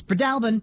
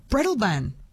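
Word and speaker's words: Breadalbane: Katrina in Roslyn says Fiona's correct. It's Breadalbin. Louise in Nowra says, Jess, I've only ever heard it as Breadalbin.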